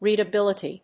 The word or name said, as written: readibility